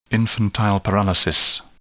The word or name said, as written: infantile paralysis